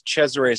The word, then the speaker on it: Cesaray: A lot of, you know, when I was growing up, nobody knew how to say Cesare or so, and it's spelled C-E-S-A-R-A-Y. So everybody would, you know, the teachers and stuff like that, they would call me Cesare or Cesara or Cesare. So then I just, you know, decided to cut it short and make it C-H-E-Z so everybody could say Chez.